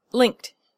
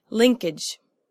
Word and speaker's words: linked: And finally, linked is also one syllable. The sound is linked. It's not pronounced linkage.